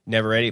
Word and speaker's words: Navarrete: Cast is Michael Johnston, Indy Navarrete, I think I'm pronouncing it right. – Navarrete.